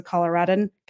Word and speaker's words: Coloradoan: Jeff also is a Coloradoan. Yeah, there's another syllable there.